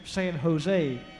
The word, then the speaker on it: San Jose: but I believe that's pronounced San Jose. The J is silent and pronounced like an H.